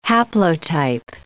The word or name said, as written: haplotype